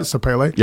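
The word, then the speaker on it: Sapele: It's Sepele. Is that how you pronounce it? Sepele. – Yep.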